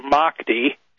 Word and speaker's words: Mahdi: Well, according to this tradition in Islam, this tradition of the 12th Imam, who's known as the Mahdi, probably pronounced Mahdi, M-A-H-D-I, it says that the Mahdi will appoint someone as his deputy to carry out the 12th Imam's work on earth.